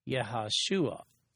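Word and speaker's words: Yehoshua (יהושע): That appears to be the intent here with regard to Joshua, which in Hebrew is actually pronounced Yehoshua.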